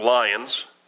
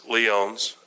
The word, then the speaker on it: Lyons: Now, if you're in Michigan, that would be pronounced Lyons, but it's Lyons, L-Y-O-N-S,